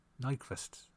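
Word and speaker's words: Nyqvist: Um, but you have Jason Bateman, Hope Davis, Frank Grillo, uh, Michael Nunquist, Lundqvist, you say his fucking name, or Michael Nunquist. I can never say his name right. – Nyquist. Nyquist? You know who the fuck I'm talking about.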